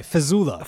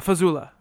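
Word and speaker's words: Fejzula: Tony Fajula. – Fajula, there you go. – Tony Vuvuzela. – Tony Vuvuzela. – Oh, I'm sorry, Fazula. – Fazula, okay.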